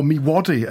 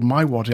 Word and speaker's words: Miwadi: Or Miwadi. It now turns out it's called My Wadi.